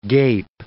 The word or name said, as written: gape